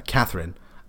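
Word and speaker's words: Katherine: Um, I'm second science officer, uh, Catherine.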